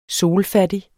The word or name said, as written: solfattig